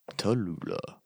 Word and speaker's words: Tallulah: Tallulah, yeah, rhymes with Cholula, so I like it.